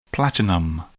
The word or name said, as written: platinum